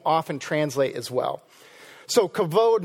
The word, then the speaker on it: kavod: Kavod. I know it has a B in it, but it's actually pronounced kavod.